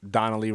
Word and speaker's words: Dannelly: Devine is spelled D-I-V-I-N-E. A lot of people confuse that. Facebook, under my actual name, Donnelly Rodriguez, D-A-N-N-E-L-L-Y Rodriguez.